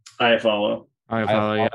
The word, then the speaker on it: Iafallo: Lafallo? – Iafallo, yeah.